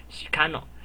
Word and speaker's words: 鹿野: 那鹿野这个词呢，在日文里面的发音就叫做 shikano。